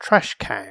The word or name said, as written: trash can